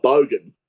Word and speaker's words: bogan: It's that five letter B word. BOGAN. – Oh, bogan.